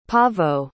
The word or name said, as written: Pavo